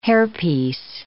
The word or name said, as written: hairpiece